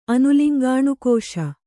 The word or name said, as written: ಅನುಲಿಂಗಾಣುಕೋಶ